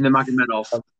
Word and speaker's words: Nurmagomedov: From the prelims, it is, you got Mark Striegel versus Saeed Nurmagomedov. Can't say his name. That one. Khabib's cousin. – Nurmagomedov.